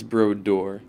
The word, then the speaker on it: Brodeur: Is it Brodeur or Brodeur? – It's Brodeur. – Brodeur. Am I saying it right? – That's the proper French way to say it, I think.